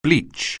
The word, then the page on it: bleach: anglais